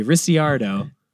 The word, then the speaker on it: Ricciardo: I'm going to go soft C. Ricciardo.